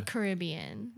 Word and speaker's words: Caribbean: It's not Pirates of the Caribbean, right? – Yes! That's right. But it's a yes, it's not, or yes, I pronounced Caribbean correctly. – Yes to both.